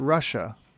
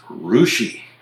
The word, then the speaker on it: Russia: How would you guess that R-U-S-S-I-A, Ohio, is named? – Okay, well, now you've tipped your hand a little bit. It's not pronounced Russia. – Right. – So this is R-U-S-S-I-A, and what state are we in? – Ohio. – Ohio. Well, I'm just going to go with the most phonetic thing, basic, straightforward, middle American pronunciation I can get out of those letters and call it Russia. – Nope, it's Rushi.